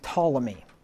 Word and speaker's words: Ptolemy: the main reason that people hold to this position is because they follow the chronology of a guy named Ptolemy. That's spelled with a P, P-T-O-L-E-M-Y, like the Ptolemies of Egypt, right, the ruling family in Egypt.